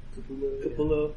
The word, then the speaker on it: Capullo: Capullo.